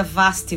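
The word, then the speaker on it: Basti: E aí a gente vai pro Vaste, né? Que se, se pronuncia Vaste, mas se escreve com B, que seria o Baste, que é a limpeza do reto, né?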